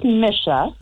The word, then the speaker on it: Misha: Okay, well, I think you got my name wrong, but this is Misha. – Oh, Misha. I'm sorry. – Saying, like, yes. It does not sound like Nissa.